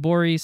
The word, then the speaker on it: Gborie: I'll just say check out David Borey's special on Comedy Central. That's spelled G-B-O-R-I-E, pronounced Borey.